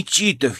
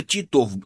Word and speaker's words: Титов: Они всегда ударение путают просто. Не Титов я, не Титов, а Титов. Это большая разница».